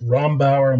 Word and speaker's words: Rombauer: is it Rombauer? Am I saying that correctly? That's right.